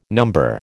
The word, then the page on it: number: American English